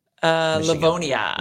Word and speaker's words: Livonia: Livonia? – Livonia.